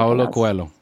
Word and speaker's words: Paulo Coelho: It's by Paulo Coelho? I can't remember his name. – Paulo Coelho. – Ah, you know how to pronounce it.